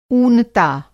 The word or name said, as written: ऊनता